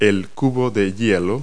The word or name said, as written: ice cube